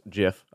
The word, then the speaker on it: GIF: Everyone I've talked to, with the exception of the GIF that you sent me, and it is pronounced GIF, I don't care what anyone says, one knows about gigabytes.